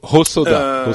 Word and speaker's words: Hosoda: Hosoda. Hosoda. Au soda, au soda. Oui, euh, c'est de l'eau. Le, euh, H est, de l'eau et le, H est sonore, donc c'est Hosoda.